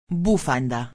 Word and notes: bufanda: espanhol

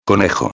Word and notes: conejo: espanhol

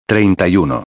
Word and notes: 31: espanhol

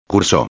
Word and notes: curso: espanhol

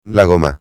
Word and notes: la goma: espanhol

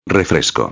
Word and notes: refresco: espanhol

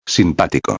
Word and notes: simpatico: espanhol

